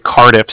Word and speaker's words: Cardiff: It sounds funny over the phone, but it's actually Cardiff, so it's spelled C-A-R-D-I-F-F, as in Frank Frank, so it's like car and then different, yeah.